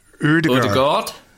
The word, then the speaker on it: Ødegaard: See, the Ødegaard. – Yeah, the D is silent.